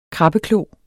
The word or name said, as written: krabbeklo